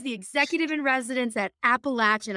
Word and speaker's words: Appalachian: They say it Appalachian. Like, if you're saying it wrong, we're going to throw an apple at you.